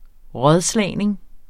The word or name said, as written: rådslagning